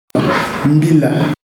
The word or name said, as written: mbila